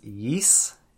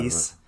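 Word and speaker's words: Ys: I ordered, I believe it's pronounced Yis, or Y-S. – Yis, yeah. – Yis.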